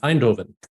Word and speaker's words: Eindhoven: Next week, you're going to be, I'm going to see how much my pronunciation guides help me, but Lille, Groningen, and Eindhoven. – That's correct. Yes.